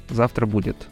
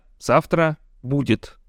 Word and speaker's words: Завтра — будет: Раз вы это слышите, значит вы включили пилотный эпизод подкаста «Завтра будет». Нет, не так. Подкаста «Завтра будет» с ударением на второе слово.